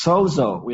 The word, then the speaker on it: sozo: It's Strong's number 4982, and it's sozo. It's S-O-Z-O. That's how I pronounce it. Sozo, I think that's right.